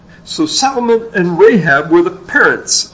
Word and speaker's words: Salmon: Nashon's son, Salmon, it's spelled like the fish, but it's pronounced differently. Salmon did enter the promised land.